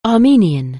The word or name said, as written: Armenian